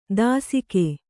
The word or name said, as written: ದಾಸಿಕೆ